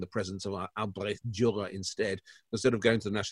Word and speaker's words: Albrecht Dürer: Albrecht Dürer. I think I have pronounced that properly. – Very good. Yes, very good. Brought up the German in you. Albrecht Dürer, indeed.